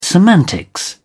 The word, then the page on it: semantics: British English